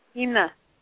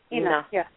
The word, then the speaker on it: Ina: Ina. – Ina. – Me. Ina. – Mother. – Mother. Or mom. – Ina. – Yeah. – Did I say it right? Ina? – Ina. – I-N-A. – Yep, I-N-A.